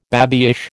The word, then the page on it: babyish: American English